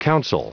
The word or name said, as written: counsel